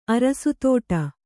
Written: ಅರಸುತೋಟ

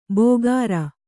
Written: ಬೋಗರ

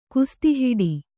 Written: ಕುಸ್ತಿಹಿಡಿ

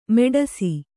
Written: ಮೆಡಸಿ

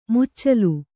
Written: ಮುಚ್ಚಲ

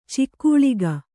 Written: ಛಗಲಕ

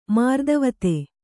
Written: ಮಾರ್ದವತೆ

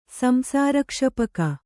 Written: ಸಂಸಾರಕ್ಷಪಕ

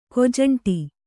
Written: ಕೊಜಂಟಿ

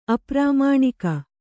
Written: ಅಪ್ರಾಮಾಣಿಕ

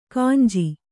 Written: ಗಂಜು

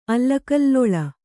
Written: ಅಲ್ಲಕಲ್ಲೊಳ

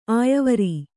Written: ಅಯ್ವರ್

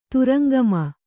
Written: ತುರಂಗಮ

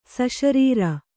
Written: ಸಶರೀರ

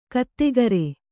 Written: ಗೊತ್ತುಗುರಿ

